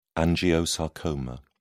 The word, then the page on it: angiosarcoma: British English